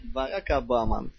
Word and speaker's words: Барак Обама: Барака Обама. – Барак Обама. Сегодня я ходил на курсы кандидатские по английскому. Она сказала, что Барак Обама... английскую букву «А» произносит как русскую «А».